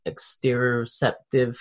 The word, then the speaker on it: exteroceptive: Is it to do with the, is it like exteroceptive?